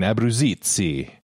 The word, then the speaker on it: Abruzzese: I go with saying it like an Italian, Abruzzese.